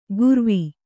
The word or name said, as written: ಕುರವಿ